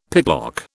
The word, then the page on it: picklock: British English